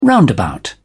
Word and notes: roundabout: British English

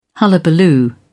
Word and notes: hullabaloo: British English

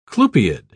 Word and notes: clupeid: British English

